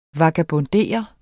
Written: vagabondere

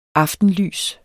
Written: aftenlys

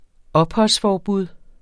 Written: opholdsforbud

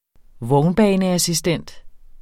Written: vognbaneassistent

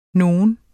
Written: nogen

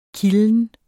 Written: kilden

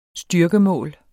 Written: styrkemål

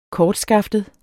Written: kortskaftet

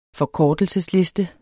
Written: forkortelsesliste

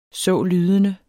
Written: sålydende

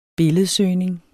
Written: billedsøgning

